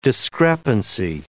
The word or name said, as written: discrepancy